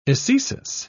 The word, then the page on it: ecesis: British English